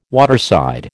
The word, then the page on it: waterside: American English